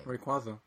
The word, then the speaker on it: Rayquaza: Rayquaza.